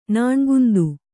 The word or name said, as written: ನಾಣ್ಗುಂದು